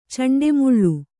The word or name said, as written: ಚಂಡೆಮುಳ್ಳು